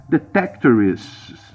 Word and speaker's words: Detectorists: Detectorist, se escreve Detectorists.